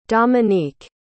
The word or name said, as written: domenique